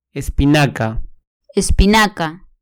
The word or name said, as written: espinaca